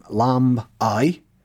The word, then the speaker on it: Lambay: But Lambay is an Old Norse name, Lamb-ai, meaning the island of the lambs.